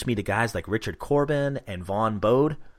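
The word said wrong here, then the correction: Bodē: I think it's Bodie. – Bodie? Okay.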